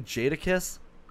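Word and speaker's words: Jadakiss: you say Jad Kiss? – Yeah, Jad Did Kiss. You mean Jada Kiss? – Yeah.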